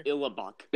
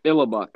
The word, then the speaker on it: Illibuck: So what's the pronunciation again just for Illibuck? – Illibuck. – Illibuck. Okay. Well, my team's called the Illibucks, and that's what I'm going with, okay? All right. So Illibucks, Illibucks. The Illibucks, okay, are going to, for the first pick, going to take Chase Young out of the Ohio State University.